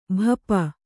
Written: ಭಾಪು

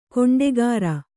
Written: ಗಂಡುಗಾರೆ

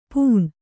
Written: ಪೂಣ್

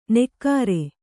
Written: ನೆಕ್ಕಾರೆ

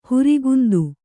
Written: ಹೊರೆಗುಂದು

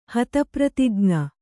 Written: ಹತಪ್ರತಿಜ್ಞ

